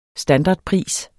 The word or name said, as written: standardpris